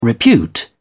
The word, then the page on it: repute: British English